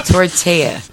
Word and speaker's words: tortilla: Tortilla. – Tortilla. – Tortilla. – Tortilla. – Okay. – Just like quesadilla, not dilla. – Yeah, but there's only one L. – Doesn't matter. You don't pronounce it.